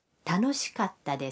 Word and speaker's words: 楽しかった: 的过去形是楽しかったです。 – 下面请大家跟着录音来练习一下发音。请注意卡达的发音，要在卡和他中间停顿一拍。